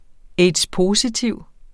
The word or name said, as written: aids-positiv